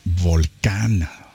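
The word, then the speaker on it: Volcán: And we listened to their brand new single, Volcan or Volcan, which is how you would pronounce it in beautiful Spanish.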